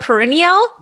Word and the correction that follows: Perrineau: Did I say that right? – Perino, but it's closer.